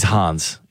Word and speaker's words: Hans: He's Hans. He's German. – I was trying to rhyme with fan.